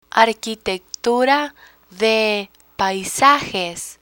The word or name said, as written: arquitectura de paisajes